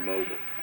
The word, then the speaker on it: Mobile: Is it Mobile or Mobile? – It's Mobile.